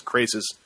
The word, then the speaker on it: Crassus: As Crassus, spelled Crassus, but pronounced Crasis, from Spartacus Blood and Sand.